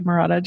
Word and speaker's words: Marotta: Welcome, Dr. Marotta.